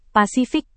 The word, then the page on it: pasifik: Indonesian